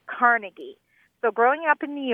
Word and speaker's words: Carnegie: Carnegie, accent on the second syllable. – With more of an A than an E.